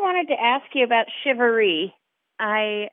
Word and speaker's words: charivari: I've seen it C H A R I V A R I, mm-hmm. but it's pronounced like S H I V A R E E.